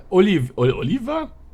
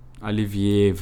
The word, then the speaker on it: Olivier: Olivier heißt der. – Olivier. Der Oliver? – Olivier wird ausgesprochen.